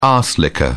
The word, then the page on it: arse licker: British English